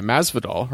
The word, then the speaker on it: Masvidal: I said it right that time. Masvidal, right? – Yes. – Masvidal. – Masvidal. – Yes. – I notoriously said that incorrectly a few times.